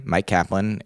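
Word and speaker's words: Myq Kaplan: So my name, Mike Kaplan, is spelled weird because I was weird and still am. As a kid, I made it up to spell it MYQKAPLAN.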